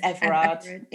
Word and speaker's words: Everard: It's Everard.